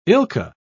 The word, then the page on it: ilka: British English